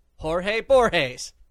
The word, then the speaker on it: Borges: His name rhymes with himself. Jorge Borges.